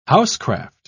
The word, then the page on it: housecraft: British English